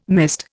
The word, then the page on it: mist: Indian